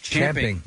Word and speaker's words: champing: Believe it or not, it's actually supposed to be pronounced champing.